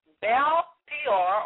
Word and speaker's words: Baal Peor: Okay, worship of Baal Peor, and for those of you, I'll spell it as Baal, or some may say Baal, B-A-A-L, dash P-O-R. It's capital P-E-O-R.